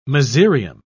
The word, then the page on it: mezereum: British English